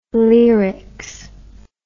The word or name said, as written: leerics